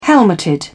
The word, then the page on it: helmeted: British English